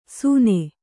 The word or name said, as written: ಸೂನೆ